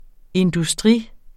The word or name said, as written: industri